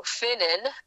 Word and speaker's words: Vynen: But, and I was able to go to Vinnen, which is spelled V-Y-N-E-N, Germany, in Northwest Germany, which is where my mom's great-great-grandfather, my third great-grandfather, lived before coming to America.